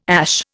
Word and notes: ASH: Indian

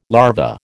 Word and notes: larva: American English

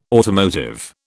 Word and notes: automotive: British English

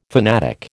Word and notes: fanatic: American English

